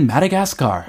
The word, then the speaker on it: Madagascar: 아, 발음이 너무 달라요. 마다가스카. 이걸 뭐라고 한다고요? – 아, 우리는 Madagascar.